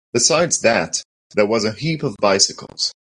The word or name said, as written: bicycles